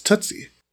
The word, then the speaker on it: Tuzzi: So that's basically my family said Tuzzi growing up. – Yeah. – Everyone called us 2Z because it looks like it's spelled 2Z. – Mm. – So we just said whatever. But technically it's Tootsie from Italy.